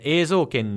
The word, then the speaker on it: Eizouken: The titles, in case people were thrown off by Katie's pronunciation, are Bakuman, Sono Biskudoru wa koi suru, and Eizouken ni wa te dasuna.